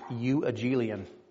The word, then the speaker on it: euangelion: In the Greek, the word gospel is pronounced euangelion. I literally wrote youangelion so that I would know how to say that.